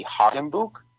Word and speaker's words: Hagenbuch: The original spelling that many of us still use, including my father and I, is H-A-G-E-N-B-U-C-H. And sort of the more German pronunciation of that would be Hagenbuch. And my father taught me to say it as Hagenbuch, Dad, you can speak to this.